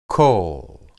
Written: kol

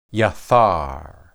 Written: yethar

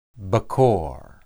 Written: bekor

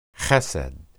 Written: hesed

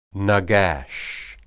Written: nagash

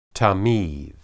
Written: tamid

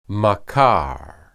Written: makar

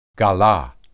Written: galah

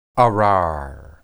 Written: arar

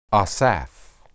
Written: asaph

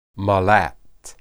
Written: malat

